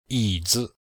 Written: yi3zi5

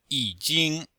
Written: yi3jing1

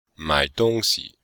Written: mai3dong1xi5

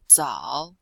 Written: zao3

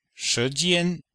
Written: shi2jian1

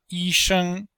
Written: yi1sheng1

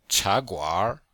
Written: cha2guanr3

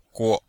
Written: guo5

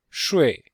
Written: shui4

